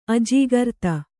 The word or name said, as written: ಅಜಾಗ್ರತೆ